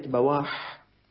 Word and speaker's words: بواح: فالكلمات التي يمكن أن تشكل نقلوها فضلا عن النطق، كتبوها حرفا حرفا تصريحا، يعني مو بس كتبوا كلمة بواحا، قال بالموحدة التحتية المفتوحة أو بفتح الباء الموحدة ثم واو ثم ألف ثم حاء، وهكذا سائر كلمات أحاديث رسول الله صلى الله عليه وسلم ليحفظوا لنا سنة النبي صلى الله عليه وسلم غضة طرية.